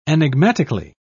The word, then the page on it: enigmatically: British English